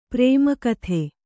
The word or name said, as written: ಪರಮಗತಿ